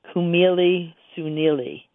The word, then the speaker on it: khmeli suneli: It's called Kumeli Sunili. And it's spelled K-H-M-E-L-I-S-U-N-E-L-I, two words.